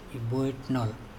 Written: iboitnol

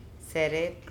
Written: SErep

